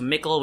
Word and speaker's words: Mickle: So shout out to Mickle, which rhymes with sickle.